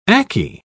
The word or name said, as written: ackee